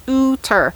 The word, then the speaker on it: Oughter: It's Loch Ooter, O-U-G-H-T-E-R, but it's pronounced Ooter.